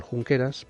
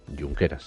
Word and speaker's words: Junqueras: Oriol Junqueras, pues resulta, perdón, Junqueras, se pronuncia Junqueras, lo siento, siempre se me olvida.